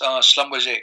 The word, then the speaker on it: Schlumberger: Yeah, so the company, so it is pronounced Schlumberger.